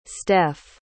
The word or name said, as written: steph